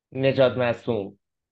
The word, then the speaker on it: Najadmasoom: Najadmasoom.